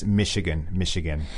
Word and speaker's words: Michigan: 对，很有名的牛排芝士三明治。好，那还有一个这个工业州呢，就是密歇根。我们前面说的 Michigan. Again, you don't say Michigan, even though it's CH. – That's right. Yeah, it's Michigan.